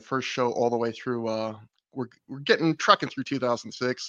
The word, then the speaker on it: ThROH: Yeah, I have a podcast with my friend Matt Feuerstein covering Ring of Honor history called Through the Years. It's spelled THROH for through, if you want to look it up.